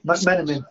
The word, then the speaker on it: McMenamin: Yeah, McMenamin.